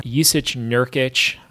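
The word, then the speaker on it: Jusuf Nurkić: The Blazers run out of starting five of Lillard, McCollum, Al-Farouq Aminu, Evan Turner, Jusic Nurkic. I think that's how you say his name. – I think you nailed it on that one.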